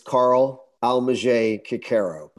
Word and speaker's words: Carl Almasy Coccaro: So it's Carl Almasy Coccaro.